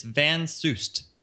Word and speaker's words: Van Soest: It's Van Soest. – Van Soest. Yes, I added a little bit extra syllable on top of it.